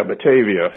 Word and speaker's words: Batavia: This was Batavia? Is that how it's pronounced? – Yeah, Batavia.